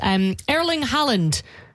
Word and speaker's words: Haaland: Erling Haaland.